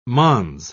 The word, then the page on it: mons: British English